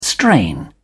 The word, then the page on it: strain: British English